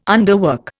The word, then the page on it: underwork: Indian